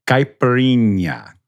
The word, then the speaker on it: Caipirinha: Yeah, this is a tongue twister for sure, especially after you've had a couple, but it's the Caipirinha.